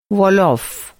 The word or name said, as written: वो